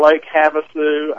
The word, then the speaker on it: Lake Havasu: He has been working with a group in Lake Havasu.